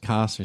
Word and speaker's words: Kos: Daily Chaos. Is that how you pronounce that fucking website? – It's just KOS or something.